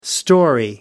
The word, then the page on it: storey: American English